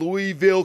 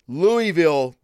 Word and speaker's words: Louisville: Another thing we got going on here in Louisville, Kentucky. Louisville, goddammit. Say it right. Louisville, Kentucky.